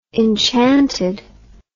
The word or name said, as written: enchanted